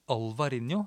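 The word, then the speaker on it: Albariño: så sier det jo V for når det står B. Og som området eh, som ligger eh, inne i Galicia, som heter Ribeiro, det uttales Riveiro. – Sånn at eh, den V-en det blir en V. Så, så spanjolene sier Alvarinho, selv om det skrives Albarinho.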